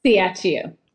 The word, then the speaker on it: Ciaccio: Ciaccio.